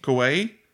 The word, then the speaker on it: Kauai: Well, he Oahu, Kauai. – Kauai.